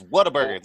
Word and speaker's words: Whataburger: Whataburger versus Five Guys. D-Pal. – It's Whataburger. There's no R or E.